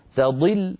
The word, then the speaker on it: تضل: تضل لابد من ترقيق التاء وتفخيم الضاد، تضي تضي، وأنا نقول تضل مثلا، اسمعوا تضل، هنا صارت التاء وكأنها طاء تقريبا.